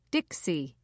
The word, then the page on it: dixie: British English